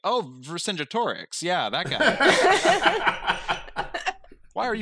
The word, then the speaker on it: Vercingetorix: And he pronounces it Vercingetorix.